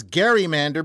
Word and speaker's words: gerrymander: Because the actual word is gerrymander because it's named after a politician in Massachusetts whose name was Gary, not Jerry.